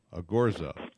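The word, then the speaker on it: Ogorzow: It's Paul, and his last name, Scott, is pronounced? – Ogorzo.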